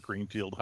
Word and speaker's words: Greenfield: Nobody can pronounce it. I go by Kirk Greenfield. Hi, guys.